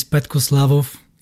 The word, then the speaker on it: Petko Slavov: My name is Petko Slavov.